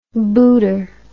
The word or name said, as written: booter